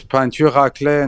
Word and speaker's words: peinture raclée: Is that how you say it? – C'est ça, yes, it's peinture raclée in French.